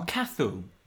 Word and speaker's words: Cathal: So, this comment comes in response to an article posted on Facebook, and it's someone called Cathal. I'm not sure that's how you say it. Cathal. C-A-T-H-A-L. – Oh, Cathal. It's an Irish name, like C-A-T-H-A-L.